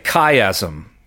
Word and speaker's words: chiasm: So that's your scholarly word for the day, chiasm. It's spelled C-H-I-A-S-M, chiasm, or another way of saying this is a chiastic structure.